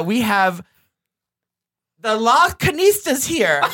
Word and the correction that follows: Las Culturistas: Is that how you say it? – Here's the how you say it. Las Culturistas.